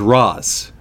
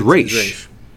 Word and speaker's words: Ra's: Dr. Barry did the research and found out that the actual Arabic pronunciation is Roz. – So I've been saying it right. – Yeah. – And the Hebrew pronunciation, which is the creators of Batman, is Raish. So you're right either way, depending on what region you're going with. – But if you're on this show, it's Ra's al Ghul.